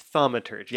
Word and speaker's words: thaumaturgy: Everyone is in consensus. This is how you say it. It's thaumaturgy.